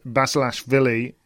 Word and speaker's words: Basilashvili: Djokovic had 2-3 setters against Martin Fucsovics and Tamura's Basilashvili.